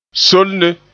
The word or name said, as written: solne